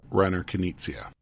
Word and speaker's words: Reiner Knizia: And it's a game by a fairly famous designer, Reiner Knizia. Is that how you say his name? I always mess that up. – Reiner Knizia. – Yeah, that's much better than what I did.